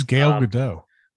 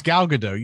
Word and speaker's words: Gal Gadot: Who's Gal Gadot? – I always thought that's how she pronounced it. – No, it's Gal Gadot. You said Gail, though.